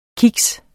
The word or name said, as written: kiks